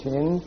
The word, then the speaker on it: shin: And if you put a point there, it's pronounced shin. If you put it there, it's called sin.